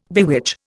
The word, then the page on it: bewitch: Indian